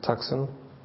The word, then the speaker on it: Tucson: And please uphold Lyle Johnson of Tucson with Tucson. That's how you say it.